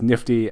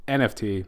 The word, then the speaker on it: NFT: According to Wikipedia, people pronounce it as Nifty. I do not pronounce it as Nifty. It is NFT.